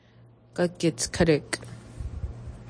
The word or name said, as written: potatoes